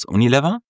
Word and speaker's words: Unilever: Nein, zu, wie spricht man das richtig aus? Unilever? – Ja.